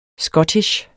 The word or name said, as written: scottish